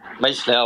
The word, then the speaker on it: Meichler: Oui, Meichler, ouais, c'est le prononcer à l'alsacienne. – Meichler, on dit EI, ouais, voilà.